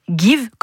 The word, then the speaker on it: Geev: Ça s'appelle Give, comme donner en anglais, sauf que ça s'écrit G-E-E-V.